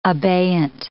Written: abeyant

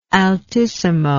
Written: altissimo

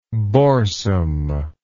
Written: boresome

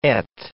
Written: et

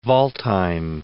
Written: Waldheim

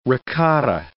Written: ricotta

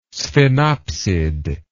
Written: sphenopsid

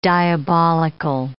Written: diabolical

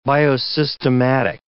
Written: biosystematic

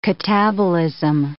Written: catabolism

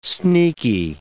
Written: sneaky

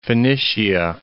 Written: Phoenicia

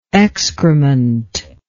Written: excrementous

